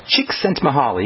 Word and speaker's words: Csikszentmihalyi: His name is pronounced Csikszentmihalyi.